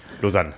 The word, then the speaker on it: Lausanne: Der hatte nämlich am äh, Wochenende zuvor das äh, äh, Grand Final der World Triathlon Series in äh, Lausanne gewonnen. – Ja, und gut Gustav ausgesprochen. Hm? hm? Gut ausgesprochen. – Lausanne. – Ja, genau. genau.